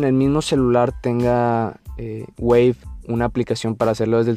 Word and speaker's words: Wavve: Wave se escribe W-A-V-V-E. Wave, con, o sea, como hola en inglés, pero con W.